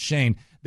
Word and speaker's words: Schoen: And there is no way that a general manager in Joe Shane, and it's spelled S-C-H-O-E-N, but it's pronounced Shane.